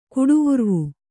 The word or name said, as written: ಕುಡಿವುರ್ವು